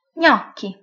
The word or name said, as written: gnocchi